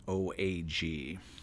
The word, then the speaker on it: Wampanoag: The feast lasted 3 days and was attended by 90 Native American, I don't know how to pronounce this word, Wampanoag. – That's how I would pronounce it, okay. Wampanoag.